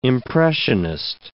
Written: impressionist

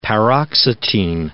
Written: paroxetine

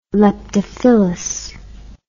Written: leptophyllous

